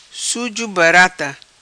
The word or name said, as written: Suzo Barata